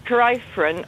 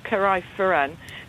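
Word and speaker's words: Carrifran: I mean, we often just say Carifran because that's how it's spelled. But it's Carifran, and it's actually got three syllables, Carifran.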